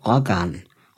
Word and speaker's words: Roggan: Wie sagt man es eigentlich richtig? – Man sagt Rogan tatsächlich. Es sagen nicht viele richtig. Aber du hast es richtig gesagt. ähm, ja, mit 2G dachte ich, spricht man das, äh, die anderen Vokale drumherum kurz aus, weil das G dominiert.